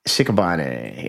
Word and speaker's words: Shikabane: How do you say her name? – Shik- Shikabane? – Shikabane. Yeah. – Does that sound right?